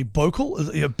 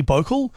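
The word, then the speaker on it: bouclé: Anyway, so this is, I think I'm pronouncing this properly. Bocal, B-O-U-C-L-E, Bocal.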